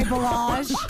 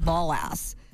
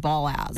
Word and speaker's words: Balazs: That's not how you pronounce it, by the way. It's called Ballass. – Ballass. – Ballass. – That sounds like a terrible joke waiting to happen. – No. That's really how you pronounce it. – Really? – Hungarian. Ballass.